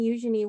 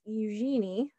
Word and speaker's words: Eugenie: I've pronounced her name Eugenie for my entire life until I've been corrected recently, and it's Eugenie, I think.